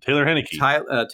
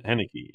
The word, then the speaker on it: Heinicke: I believe Taylor Heinicke. – Taylor Heinicke. Okay. – Heinicke, yeah.